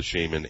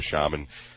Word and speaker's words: shaman: But the lawyer last night that I watched and listened to for the shaman and or shaman, which is a very unusual word that evidently can be pronounced either way.